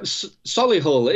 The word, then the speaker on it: Solihull: And Sola Hill Publishing Company. Hope I said that correctly. Was I close? – You know, Solihull, it's actually where I was born.